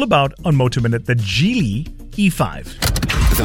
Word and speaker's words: Geely: Some people call it Geely. It's not called Geely. It's called Geely. Yep, it's called Geely. It's spelled G-E-E-L-Y, right?